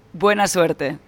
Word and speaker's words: buena suerte: And buona suerte, am I saying that right, to Spain tomorrow? Hasta luego? – Kind of. Yeah, kind of. – Don't be kind. How should I have said that? Come on. – Suerte. – Buena suerte.